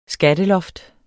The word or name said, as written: skatteloft